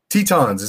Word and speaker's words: Tetons: Grand Tetons. – Tetons. Is that how you pronounce it? Tetons? – Yeah. I'm pretty sure it's Grand Tetons.